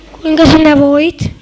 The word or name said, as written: voit